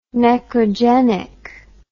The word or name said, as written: necrogenic